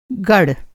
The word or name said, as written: गड़